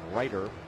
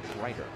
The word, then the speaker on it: Reiter: Opponents hit 238 against the left-hander Brandon Ryder. Ryder is spelled R-E-I-T-E-R, Ryder.